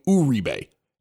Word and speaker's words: Uribe: He goes to the Giants and they're calling him Uribe. And it was like, wait, he changed his name? Nope, that's how you're supposed to pronounce it.